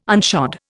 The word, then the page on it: unshod: Indian